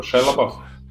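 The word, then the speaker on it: Shia LaBeouf: а женщину-серферку зовут Шалабал. И они... – Подожди, правильно говоришь Шайаба. Как, господи, как его? Шайлабаф.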